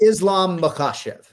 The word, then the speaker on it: Islam Makhachev: I mean, the thing is, like, we can all nitpick, but basically you emphasize the right syllable and you fucking nailed it, Dale. I'm sorry. I just slipped. – It's okay. Because I give Trey all kinds of problems because he is awful at pronunciation. – It's Islam Makhachev. – Makhachev. Okay, that's what I thought.